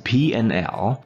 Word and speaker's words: P&L: Lesson 3 P and slash L, pronounced as P and L, is the acronym for profit and loss.